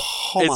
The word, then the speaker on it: homage: It's homage. It's homage. – Homage. – The H is silent.